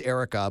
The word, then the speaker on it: Airwrecka: And then Erica, pronounced Erica, but spelled A-I-R-W-R-E-C-K-A. Erica.